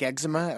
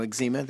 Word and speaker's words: eczema: and then brought a book in and said it looks like eczema, or, you know, eczema, that's pronounced both ways.